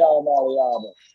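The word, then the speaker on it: Iamaleava: Yeah, Iamalavea.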